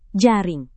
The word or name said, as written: jaring